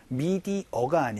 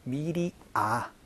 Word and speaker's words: media: 미디어가 아니고 미디아, 미디아, 아로 발음된다라는 거 조금 주의를 해주시면 좋을 것 같아요.